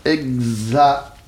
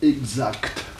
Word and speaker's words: exact: Bon, écoutez, actuellement, les deux prononciations existent. Vous ne pouvez pas dire que l'une soit bonne et que l'autre soit mauvaise. Voyez-vous ? Les deux existent. Voyez-vous ? Seulement, vous pouvez les juger d'une façon différente. L'ancienne prononciation, si vous voulez, l'ancienne, quelle est-elle ? – Exact. – Exact. Voyez-vous, sans que te.